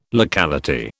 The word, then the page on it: locality: British English